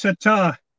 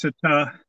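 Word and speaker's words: Sertã: I think it's should be pronounced Sertar. – Sertar. – Because the accent is over the A. So yeah. The rule generally being that the accentuation is on the accent. So I think it's Sertar.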